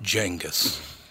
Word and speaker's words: Genghis: And right in the middle of the conversation, he leans over and goes, "It's pronounced Genghis."